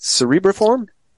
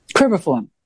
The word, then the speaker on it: cribriform: cerebriform? – Cribriform.